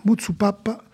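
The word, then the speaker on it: Muzzupappa: Mutsupapa, il faut bien le prononcer, Mutsu avec deux Z et deux P.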